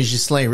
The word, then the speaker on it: Ghislain: Nobody calls me Ghislain, really.